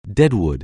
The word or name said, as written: deadwood